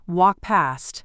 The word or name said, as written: walk past